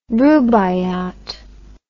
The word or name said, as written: rubaiyat